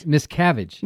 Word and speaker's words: Miscavige: Miscavige. – Miscavige. That's spelled M-I-S-C-A-V as in Victor, I-G-E, Hill, author of the new book, It's getting a lot of publicity.